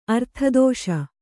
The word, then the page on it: ಅರ್ಥದೋಷ: Kannada